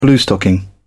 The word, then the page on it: bluestocking: British English